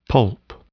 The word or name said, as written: pulp